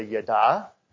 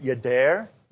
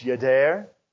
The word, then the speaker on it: yada: It's translated from the Hebrew word, and I tend to say yada. It's hard to stop that, but I did listen on Blue Little Bible this week, and they pronounce it yadair. You know, Terry probably smirks every time I try to speak in Hebrew. It's a little rough around the edges, but in their pronunciation, it's yader.